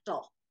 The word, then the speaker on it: Bechdel: Bechdel.